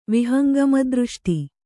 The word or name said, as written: ವಿಹಂಗಮದೃಷ್ಟಿ